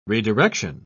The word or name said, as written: redirection